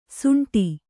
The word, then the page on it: ಸುಂಠಿ: suṇṭhi